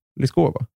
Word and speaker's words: Lyskova: Men du säger fel då. För du är så van vid att folk säger Sven uttal. – Mm. Och egentligen är det Lyskova. Alltså man säger L-et så, men det är överkurs. För det är ryskt.